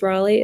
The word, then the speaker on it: Raleigh: So my Instagram is Alexis Raleigh.